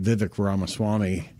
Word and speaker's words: Vivek Ramaswamy: Vivek Ramaswamy, and I finally was able to say his name correctly, had suspended his presidential campaign and came out and did endorse Donald Trump.